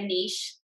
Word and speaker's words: niche: niche. Is that how you say that word? – Mm-hmm. Yeah. You said it right.